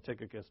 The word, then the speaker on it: Tychicus: But anyway, the accent is on the last syllable, so it's Tychicus. It just doesn't sound right in English, so we'll call him Tychicus.